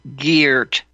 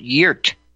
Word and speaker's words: Geert: I didn't listen carefully enough, but they say Geert. They don't make it a hard Geert. They make it Geert. Geert Wilders.